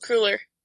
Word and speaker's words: Cruller: I think it's Pruler? Was that right? Yes? Yeah, I think it's Cruller. Devonshire Cruller. – Cruller? – Yeah, like the pastry.